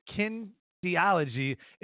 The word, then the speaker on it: kinesiology: Clifford graduated in, how do you say that, kinesiology? – Kinesiology.